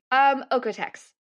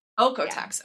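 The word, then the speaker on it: OEKO-TEX: Is it Oikotex? Ecotex? How do you say that? – Oikotex. – Oikotex. Yeah. Okay, cool.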